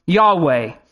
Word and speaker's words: Yahweh: In Hebrew, it's pronounced Yahweh, which comes from the word.